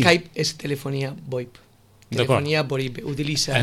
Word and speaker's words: VoIP: Podem parlar de la telefonia VoIP. – Sí. – Lo he dicho bien, esto? – Sí. Sí. VoIP.